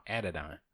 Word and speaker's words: Adidon: Phonetically speaking, it's Adidon. – Yeah. – Adidon.